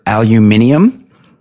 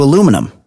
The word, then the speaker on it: aluminium: So, you know, both aluminium and aluminum are correct because the guy that invented or discovered aluminium, he named it aluminium. And then after it was named that, he changed the name to aluminum. So both pronunciations actually correct. Aluminium and aluminum.